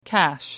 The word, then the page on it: cash in: American English